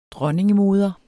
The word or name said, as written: dronningemoder